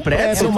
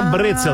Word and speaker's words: Brezel: É um pretzel, mas a pronúncia correta é Brezel, que se diz.